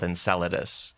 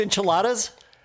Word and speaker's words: Enceladus: And the whole bonus show will be about two of them, one called Titan and one called Enceladus, because both of them have... Oh, it's not pronounced enchiladas? – It should be. – I've only ever seen it written. Looks like it's written as enchiladas.